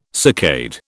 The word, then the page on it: saccade: British English